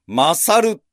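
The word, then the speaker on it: 神猿: 神の猿と書いて、マサル。神の猿と書いて、マサル。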